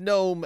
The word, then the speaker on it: GNOME: Notice I said GNOME and not GNOME because GNOME is not a G word. GNOME. A GNOME is a thing. The G is silent.